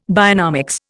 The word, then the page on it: bionomics: Indian